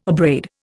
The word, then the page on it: abrade: Indian